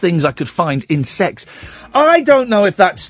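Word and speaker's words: CeX: I'm being told by an ever-growing number of people that Kex Computer Exchange is pronounced as sex.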